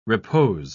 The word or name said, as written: repose